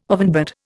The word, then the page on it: ovenbird: Indian